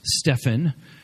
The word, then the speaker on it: Stephen: By the way, Steph Curry, 7th overall round 1 draft pick, pronounced Stephen. Wardell Stephen Curry II, Steph, baby-faced assassin, Chef Curry, the human torch, Threesus.